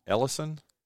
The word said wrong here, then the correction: Elysian: Elysian. – Elysian, thank you.